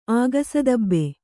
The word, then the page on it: ಆಗಸದಬ್ಬೆ: Kannada